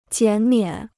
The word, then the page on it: 减免: jiǎn miǎn